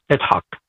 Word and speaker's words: Yitzhak: by the way, what Isaac, which in the real pronunciation is Yitzhak.